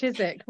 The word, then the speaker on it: Chidzik: That is correct, Chidzik. Well, we sort of anglicise it, but yes, not far off.